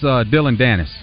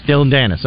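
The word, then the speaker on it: Dillon Danis: It's Dylan Danis. – Dylan Danis, okay. Just want to make sure I pronounced it right.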